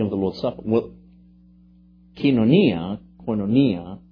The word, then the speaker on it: koinonia: koinonia, right? Koinonia, which is the correct pronunciation of that.